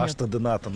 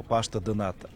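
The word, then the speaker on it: pastel de nata: Паште де Ната называется. Пишется паштель де Ната, а называют они его паште де Ната.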